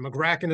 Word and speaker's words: Magraken: So your first one is spot on. Magraken is good. – Magraken. – And your third one is probably more how it's supposed to be pronounced. It's an Estonian name. I don't speak Estonian, but people that do pronounce it the third way that you did.